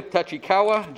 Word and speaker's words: Tachikawa: All over the country, all over the world, all the way to Tachikawa, Japan.